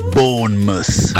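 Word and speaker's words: Bournemouth: Ma il Bornemont quanto gli offrirebbe di stipendio a Zaniolo? Perché qui si parla solo che ha offerto alla Roma 30 milioni. – Valentina, si pronuncia Bonmus. Aia. Bonmus. Mus, mus, mus.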